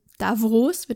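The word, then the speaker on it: Davos: der ja in Davros, wird das ja ausgesprochen, habe ich gelernt, mhm.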